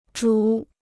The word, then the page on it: 主: zhǔ